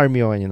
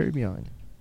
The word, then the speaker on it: Hermione: Não é a Hermione não, Guilherme, é Hermione.